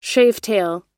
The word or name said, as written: shavetail